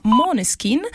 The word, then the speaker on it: Måneskin: Maneskin infatti si pronuncia Måneskin e significa chiaro di luna, e scommetto che questa proprio non la conoscevate.